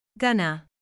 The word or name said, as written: gonna